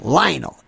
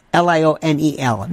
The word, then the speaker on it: Lionel: Use Lionel, L I O N E L, not Lionel. I don't know why people say this. Like Lionel rhymes with vinyl, one L.